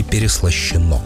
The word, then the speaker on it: переслащено: Я сразу полез проверить себя на ударение. Все верно, переслащен. Если есть «ё», она ударная. А вот в женском и среднем роде нет «ё». Поэтому там «переслащена» и «переслащено».